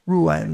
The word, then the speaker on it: Ruane: Jack Ruan, that's spelled R-U-A-N-E. It may not sound like an Irish name, but it is.